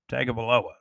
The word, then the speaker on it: Tagovailoa: How do you say Tua's last name? Tagovailoa. That's it. I always have trouble with that name. That's a tough name.